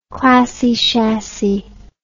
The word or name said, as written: classy chassy